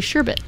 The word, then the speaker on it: sherbet: It is, because a lot of people throw an R in there and they say sherbet, but it's actually sherbet. – It's like Herbert.